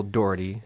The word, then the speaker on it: Dougherty: Michael Dougherty, uh-huh.